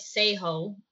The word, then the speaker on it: Seijo: So my last name is Seijo, but it's spelled S-E-I-J-O.